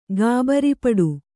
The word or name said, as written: ಗಾಬರಿಪಡು